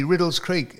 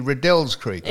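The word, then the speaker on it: Riddells Creek: Now, what about Riddle's Creek? – Exactly, Riddle's Creek. It's actually Riddell's Creek. – It's not, it's Riddle's.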